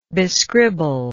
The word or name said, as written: bescribble